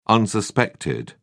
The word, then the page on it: unsuspected: British English